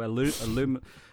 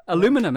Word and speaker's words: aluminum: You could be a real fucking prick and just put batteries inside your aluminium wrap. – Aluminum. That's what Americans say.